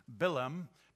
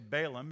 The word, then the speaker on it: Balaam: Now, his name is pronounced Bilam, but we always say Balaam because we see the A's as English speakers, and that's the way I'm going to say.